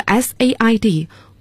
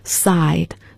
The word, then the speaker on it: said: 所以要读成 says，这个 said 过去式过去分词也要读成 said as in bed，而不可以读成 side，大家要注意这三个词的发音。